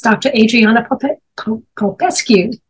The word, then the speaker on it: Adriana Popescu: Again, that was Dr. Adriana Popescu.